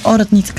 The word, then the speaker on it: Оратница: Ние поканихме група Оратница, или Оратница, както Демай предпочита да се наричат, аз бъркам понякога ударението, и един от най-известните български каба гайда, гайдари, Петър Янев.